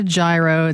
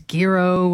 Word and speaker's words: gyro: It's not a gyro. It's gyro, whatever it is.